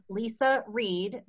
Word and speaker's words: Leisa Reid: It's Leisa Reid, but it's L E I S A R E I D.